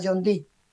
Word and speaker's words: John Dee: Según una nota hallada que había llegado a Inglaterra en el siglo XVI, este espejo había pertenecido al célebre matemático y ocultista John Dee.